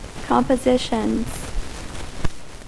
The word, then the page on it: compositions: American English